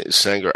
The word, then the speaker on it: Saenger: So, this was at the Sanger, Say, yeah, Sanger, S-A-E, yeah, Sanger. I've always said Sanger. I frankly, now that I think of it, I, I never heard anybody pronounce it. Um, S A E N G E R.